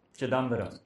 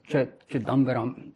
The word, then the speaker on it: Chidambaram: I've been in India for a month now, and I traveled all the way, and I've written it down because of the pronunciation of the places. Chittambaram. – Chidambaram. – Chidambaram.